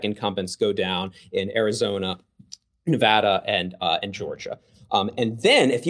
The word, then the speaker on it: Nevada: and Nevada. I said it correctly. – Nevada.